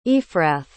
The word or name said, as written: ephrath